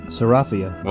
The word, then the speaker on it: Ceriphia: Ceriphia.